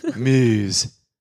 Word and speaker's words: Meuse: Meuse. I'm not even trying to pronounce that wrong. I reckon that's exactly how you pronounce it.